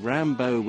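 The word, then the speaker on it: Rimbaud: Yeah, but knowing about the love of Verlaine and Rambo and rhyming Rambo with "You're going to make me lonesome when you go." I mean, the G-O rhymes with B-A-U-D.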